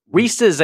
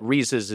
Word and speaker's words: Reese's: Reese's, I think, is how it's supposed to be pronounced, but Reese's is a regionalism.